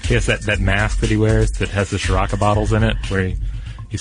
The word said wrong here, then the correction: Sriracha: Oh, I said it wrong. I always say it wrong. I always say Shiraka, but I don't. I guess there's not even an H in there.